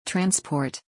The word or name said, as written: Transport